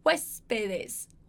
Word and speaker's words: huéspedes: Ó, então uma coisa super legal pra você aprender a respeito da pronúncia dessa palavra. Foca em mim aqui agora, hein? Foca aqui. Huéspedes. Huéspedes. Huéspedes.